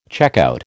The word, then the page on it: checkout: American English